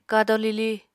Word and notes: kado-lili: Apatani

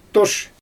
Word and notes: toʃ: Xyzyl

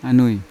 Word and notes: anui: Yawa

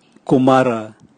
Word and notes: kumala: Raga

